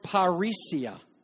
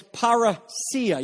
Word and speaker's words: parousia: This word that is translated openly is the Greek word parousia. Now, I just said it wrong, and I'll tell you why. Because this is how I learned it in Greek class. But it's parousia. You see the iota at the end, and you see that acute accent?